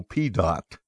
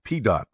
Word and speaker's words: Pedott: And one of those guys on this board that my brother Mike got to know quite well was a fellow named Joe Pedott. That's how he pronounced his name. P. Dot. P E D O T T.